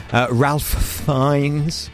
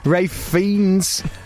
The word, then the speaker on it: Ralph Fiennes: Ralph Fiennes. Fiennes. Rafe. It's Rafe, isn't it? It's not even Ralph. It's Rafe. Rafe Fiennes and Tilda Swinton.